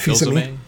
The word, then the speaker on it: Fils-Aimé: Reggie Fils-Aimé.